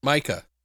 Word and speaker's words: Micah: How do you say his name? M-I-C-A-H. – Micah. – Micah. Yeah, that's right.